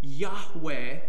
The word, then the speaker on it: Yahweh: But when he's revealing his personal name here, "I am," Yahweh is how it's pronounced, He's showing us how he is a God who is close.